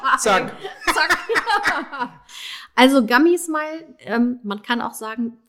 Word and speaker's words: Gummy Smile: nämlich Gummy Smile. Habe ich es richtig ausgesprochen? – Du hast es perfekt ausgesprochen. Ich hätte es nicht schöner sagen können.